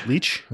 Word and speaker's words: Lich: How do you pronounce her last name? L-I-C-H. – Leach. – Okay. – Tamara Leach.